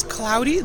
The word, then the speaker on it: Claudi: My name is Cloudy, that is spelled C-L-A-U-D-I, and I'm the founder of Pink Louds, sure.